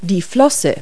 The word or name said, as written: flipper